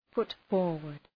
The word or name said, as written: put forward